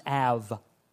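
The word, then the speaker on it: Av (אב): It's spelled Ab, but it's pronounced Av or Av.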